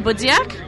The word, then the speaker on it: Budziak: My name is Maggie Budziak. Budziak is spelled B-U-D-Z-I-A-K, just for the record.